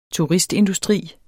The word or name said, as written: turistindustri